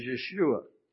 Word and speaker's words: Yeshua: But the way that they would have pronounced it was Yeshua.